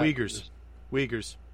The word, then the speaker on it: Uyghur: Uyghur is how you say it. It starts. Oh. It's like UIG something. That's how it's spelled, but it's pronounced Uyghur.